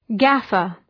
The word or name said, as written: gaffer